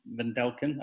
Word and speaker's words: Wendelken: And via the internet from various locations in New York and Connecticut, it's the Swish FM radio boys, Chris Vindelken.